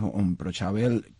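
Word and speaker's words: imbrochável: que es Improchable, como se pronuncia porque es portuguesa la palabra, la estuve oyendo varias veces.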